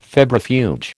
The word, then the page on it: febrifuge: American English